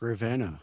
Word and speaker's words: Ravenna: Now, is that how you pronounce that, Ravenna, or is it Ravenna? – It's Ravenna.